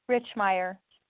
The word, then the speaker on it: Richmeyer: And Wendy Reichmeyer, is that the right way to say your Richmeyer. – Last name, Wendy? – Richmeyer, with a C-H.